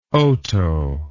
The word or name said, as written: otoe